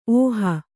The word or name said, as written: ಓಹಿ